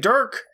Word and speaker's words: Dirk: If you're wondering why I keep saying Dirk, it's his first name. Rhymes with jerk.